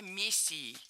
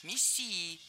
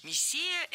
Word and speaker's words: мессия: Он считал себя мессией. Неверно. Правильный вариант – мессией. Мессия – это слово с древнегреческими корнями. Произошло оно от глагола, означающего «мазать», и буквально означает «помазанник». Но и ударение в этом слове падает на второй слог – «мессия».